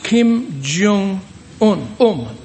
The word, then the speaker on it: Kim Jong-un: You know, the leader of North Korea, and I think his name is correctly pronounced Kim Jong-un. I think that's right, or it's very close to it.